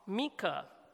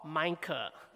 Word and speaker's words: Micah: So today we'll begin by looking at the book of Micah, or Micah. Some people pronounce as Micah, some people pronounce as Micah. It really depends on which where you come from,